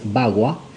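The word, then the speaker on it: Pakua: Hay un conocimiento chino ancestral que es el conocimiento Pacua. P-A-K-U-A o se también eh, se puede encontrar con B larga la primera y G en vez de K, o sea que es Bagua,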